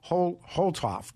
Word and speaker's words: Holtorf: And Colorado State Representative Richard, and it's pronounced Holtorf, T-O-R-F.